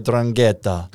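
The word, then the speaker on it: 'Ndrangheta: Drangheta, it. Yeah, I don't think you pronounce the end, so it's like Drangheta.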